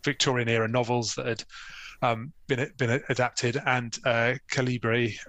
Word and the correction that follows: Cabiria: I always get the pronunciation of that one wrong. – Cabiria.